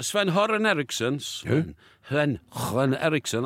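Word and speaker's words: Sven-Göran Eriksson: Well, he was England coach under Sven Hörn Eriksson, that's the way it's pronounced in Swedish, Hörn Eriksson.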